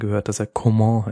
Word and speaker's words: Coman: Und endlich wird Kingsley Coman beziehungsweise Coman richtig ausgesprochen.